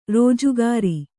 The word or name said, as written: ರಾಜಗೀರೆ